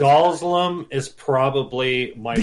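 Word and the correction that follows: Dhalsim: Say the name right. – I know. – Dazzlim. – How do you pronounce his name? – Dalsim.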